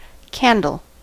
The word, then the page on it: candle: IPA: /ˈkændəl/